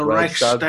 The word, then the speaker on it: Reichstag: The Reichstag? How do you pronounce that one? – The Reichstag.